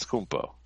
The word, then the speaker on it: Antetokounmpo: But Antetokounmpo, I said it right. I definitely. – Antetokounmpo. – I think I blew. Kumpo. Is it Kumpo? – It's Kumpo.